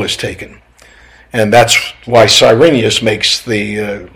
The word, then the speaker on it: Quirinius: But during the birth of Christ, There is one who takes the governorship whose name is Cyrenius, and sometimes pronounced with a Q, or Quirinius.